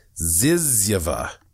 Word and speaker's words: Zyzzyva: Usually in most dictionaries, the last word in the English language is zizyvia. Okay, let me spell that for you. Z-Y-Z-Z-Y-V-A. Zizyva.